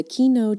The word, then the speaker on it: Quino: According to the Center for Biological Diversity, the Kino checker spot, which is spelled Q-U-I-N-O, not keynote, as I thought he was saying during the interview.